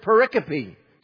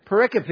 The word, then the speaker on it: pericope: So I want to introduce you to a word, and the word is pericope. Kind of a cool word, pericope.